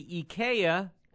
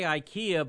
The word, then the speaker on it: IKEA: She was at the IKEA. – Uh-huh. – You say IKEA, but that's wrong. We say IKEA. – IKEA is the way it's supposed to be pronounced.